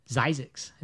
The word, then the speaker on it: Zzyzx: Zizix is how it's pronounced.